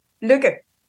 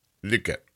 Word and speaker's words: Lykke: You sort of need to give up on the last part of the name, and then you need to pronounce the Y. It's pronounced Luca.